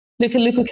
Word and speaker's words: Lukuluku: I'm going to try and say this right, Lukuluku campaign. – Lukuluku campaign, yes.